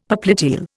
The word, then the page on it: popliteal: Indian